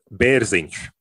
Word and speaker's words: Berzins: Berzins.